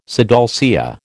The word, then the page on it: sidalcea: American English